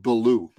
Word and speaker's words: Billou: I am blessed and grateful, but my last name, pronounce it Billou. It's simple. – Billou. Oh, I'm sorry. I'm pronouncing it like it's a French last name.